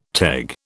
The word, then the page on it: teg: British English